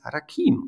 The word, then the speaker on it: Rakim: es ist nur eine Betonung, aber es ist wichtig, wie wir einen Namen aussprechen. Nein, es ist Rakim. Und da habe ich ihm gesagt, nein, wir bleiben, das ist eine jambische, jambische, ich bin ein bisschen albern als Deutscher das erklärt, aber ähm, du bist Rakim und jeder soll dich Rakim nennen.